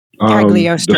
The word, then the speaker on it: Cagliostro: Strange jumps into the ancient past looking for the library of Cagliostro. Did I say that right? – Cagliostro. – The source of the Eye of Agamotto. There we go.